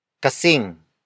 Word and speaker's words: kasing: Kasing. Kasing. Kasing. Kasing is spelled as K-A-S-I-N-G.